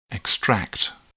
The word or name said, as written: extract